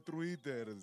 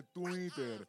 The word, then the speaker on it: Twitter: No se dice Twitter, se dice Twitter.